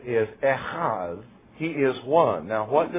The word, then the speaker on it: Echad: Echad is the way it's pronounced if you're going to pronounce it correctly in Hebrew.